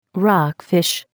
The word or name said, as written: Rockfish